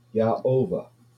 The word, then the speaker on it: Yahovah: And it's properly pronounced Yahovah. Yah as in Hallelujah, Ovah as in Jehovah.